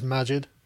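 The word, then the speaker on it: Maajid: And today we're going to be talking about some things, including the red pilling of Majid Nawaz and ultimate deplatforming. – I think it's Majid. – Majid. Oh, yeah. Is that? I've been pronouncing it wrong this whole time.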